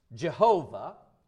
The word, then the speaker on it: Jehovah: Now, sometimes that name is pronounced Jehovah, and the reason is because the Jewish people would not say the name of God.